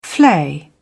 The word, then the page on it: flay: British English